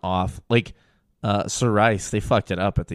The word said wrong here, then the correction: Cerise: Sir Rice. How do you say it? – Cerise. – I always call it Sir Rice. I don't know. Fuck me. Cerise.